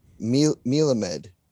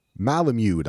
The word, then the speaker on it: Melamed: Melamed? – Oh. Malamud, I think, is his name. Is that the way you pronounce it? – M-E-L-A-M-E-D. – Oh, okay. Malamud. – Malamed.